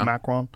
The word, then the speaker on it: Macron: Macron? – Macron.